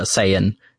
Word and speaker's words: Saiyan: And did you pronounce it Scion? – How is it fucking pronounced? I don't watch Dragon Ball. – That's certainly... That's like half and half what the English and Japanese then would be. In England, over in America, we call it a Saiyan.